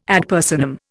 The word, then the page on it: ad personam: Indian